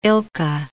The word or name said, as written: ilka